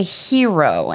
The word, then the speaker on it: Hiro: So when visiting Anaheim, he suggests Cafe Hiro, and I think I'm saying that right. – Yeah, you are. H-I-R-O.